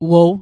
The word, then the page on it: wow: Mi'gmaq